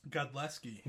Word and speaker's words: Godlewski: Godleski.